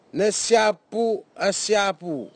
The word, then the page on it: nesiapuasiapu: Nafe; Tanna